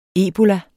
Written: ebola

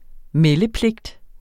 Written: meldepligt

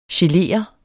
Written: gelere

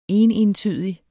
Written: enentydig

